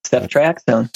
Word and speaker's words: ceftriaxone: With a tick-borne infection with, I can't even pronounce it, ceftriaxone? – That's right, ceftriaxone.